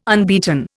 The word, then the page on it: unbeaten: Indian